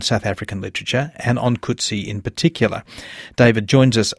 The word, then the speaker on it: Coetzee: Yes, it's Coetzee. I think if you wanted to pronounce the name with its full Afrikaans resonance, you would have to say something like Coetzee. But John Coetzee comes from the Cape, where there's a lot of bilingualism, and I think there's a slightly English emphasis in the pronunciation of the name, so Coetzee is reliable.